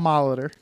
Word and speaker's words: Molitor: It's Paul Molitor.